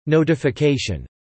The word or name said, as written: notification